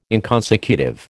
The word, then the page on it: inconsecutive: American English